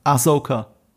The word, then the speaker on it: Ahsoka: Ahsoka. Ahsoka.